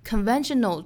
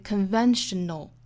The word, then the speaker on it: conventional: Conventional 这个词它的重读音节是在第二个音节上，是在 ven 这个位置，所以 conventional conventional wisdom 还要注意这两个词的搭配。